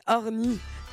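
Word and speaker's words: Horny: Je l'ai prononcé vraiment comme ma grand-mère. C'est Orny et c'est tout de suite sur Europe 1.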